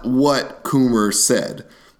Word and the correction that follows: Comey: Did I say that right, Coomer? Is that how you say his name? – No, Coomey. – Coomey. – Is it Coomey? – Coomey. – C-O-M-E-Y. Coomey.